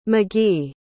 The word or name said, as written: magee